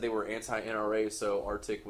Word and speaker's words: RTIC: One of them split off and got bought out and took the same technology and went and made Arctic. It's spelled RTIC.